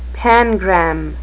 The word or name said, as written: pangram